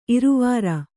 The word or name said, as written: ಇರವಾರ